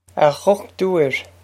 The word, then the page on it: A dhochtúir: Ah guck-toor